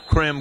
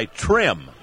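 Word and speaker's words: Chrim: His last name is spelled C-H-R-I-M-C-O-R-D-E-R-O, but he doesn't go with Crim Cordero. He goes by Trim instead of Crim even.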